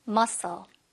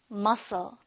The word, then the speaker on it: muscle: 이거 발음할 때는 중간에 얘도 쓰고 얘도 쓰잖아. 탈락시키고 한 번만 발음해 주시면 돼요. Muscle. 한번 더. Muscle.